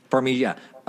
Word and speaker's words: Farmiga: Farmiga.